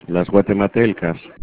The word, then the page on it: guatematelcas: Spanish